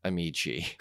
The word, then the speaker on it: Ameche: Because I pronounced it exactly like you did yesterday, and they said, Ameche.